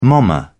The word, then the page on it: momma: British English